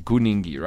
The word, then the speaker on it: Kuningi: Yeah, but it's Kuningi, not Kuningi.